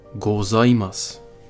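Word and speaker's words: Gozaimas (ございます): Augenbemerkung, das japanische Z wird nicht als Z ausgesprochen, wie in der deutschen Sprache, sondern, sondern als Z, wie eine Summe von einer Flüge. Das Wort Gozaimas ist am Anfang mit einem Z geschrieben und am Schluss mit einem S, also Gozaimasu. Aussprache ist Gozaimas.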